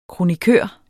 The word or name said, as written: kronikør